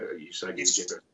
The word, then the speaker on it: Usagi Yojimbo: Usagi Yojimbo, is that Usagi Yojimbo? – That's how I say it.